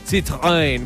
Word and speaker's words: Citroën: Vamos a tener por allá Honda, vamos a Zanautos, Citroën con Alcalá Motor, perdón, lo dije mal. Citroën, porque es una marca francesa.